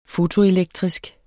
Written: fotoelektrisk

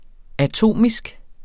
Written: atomisk

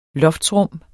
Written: loftsrum

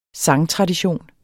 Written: sangtradition

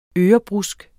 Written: ørebrusk

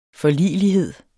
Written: forligelighed

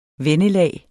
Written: vennelag

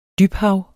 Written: dybhav

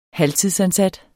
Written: halvtidsansat

